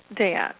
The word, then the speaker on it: Da'at: Da'at. Sorry, I was initially taught that was Da'at, so you know how you get that first pronunciation. – Well, some people do that because it rhymes with Ma'at.